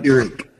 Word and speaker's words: Pyrrhic: That type of paradigm is one of those paradigms that creates this what one would call a pyrrhus victory. I'm probably mispronouncing it. Do you know what I'm talking about? – Pyrrhic. – Pyrrhus? – Pyrrhic. – Pyrrhic, thank you.